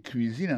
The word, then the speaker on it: cuisine: Autant de cuisine là-dedans, c'est comme ça on dit cuisine, on prononce cuisine en anglais. C-U-I-S-I-N-E. C'est cuisine.